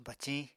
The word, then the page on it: baciñ: Apatani